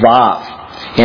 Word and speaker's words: Vav: And it's actually pronounced with V's. Instead of W's, put V's in there. And it's Vav is actually the letter.